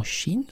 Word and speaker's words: qi: En Inde, il est connu sous le nom de prana et en Chine sous le nom de qi, qui se prononce aussi chi.